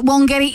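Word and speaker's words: Wangetti: And we called it Wongetti even though it's spelled W-A-N-G.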